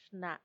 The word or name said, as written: xna